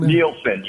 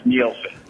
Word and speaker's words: Nielsen: Oh, is your last name Nielsen, spelled with an N? – Nielsen, yes. – Oh, perfect. Okay, great. – Yes, Nielsen.